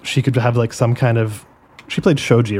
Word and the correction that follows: shogi: right? – Yeah. – Yeah. So like they could have like some kind of Shoji mechanic. – Shogi. – I don't remember how to pronounce. Apologies. – I think Shoji is a name. Shogi. Oh, I don't remember is the game. – Shogi. Yeah. Shogi. Yeah. There we are. That's correct.